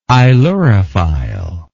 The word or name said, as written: ailurophilic